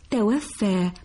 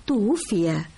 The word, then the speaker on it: توفي: عندما قرأت بالأمس نعي الجامعة قلت توفى بفتح الواو والفاء، وذكرت لي أن الصواب توفي، وسألتك عن الاختلاف في المعنى بين النطقين، وهذا ما سنعرفه اليوم.